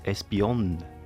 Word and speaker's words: Espionne: Und ich glaube, es im Radio hat es dort es Espion genannt, mit Betonung mhm. auf dem N.